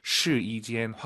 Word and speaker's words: 试衣间: 숫자 1이 아니라 여기서는 YI 발음, 시이 아니고 시이, 시이젠. 제가 이렇게 왜 말씀을 드리냐면 한국인 초급자분들이 가장 어려워하는 게 사성담의 일성이에요. 따자 이 발음을 따자, 막 사성이 일성이 돼버려요. 네, 그래서 시이젠 확실하게 시이, 이를 확 올려서 발음하셔야 됩니다.